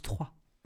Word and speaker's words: Troyes: La ville se prononce Troyes.